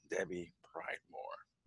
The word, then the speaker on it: Debbie Pridemore: Today I have a colleague of mine, Debbie Pridemore.